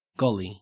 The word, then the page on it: golly: Australian English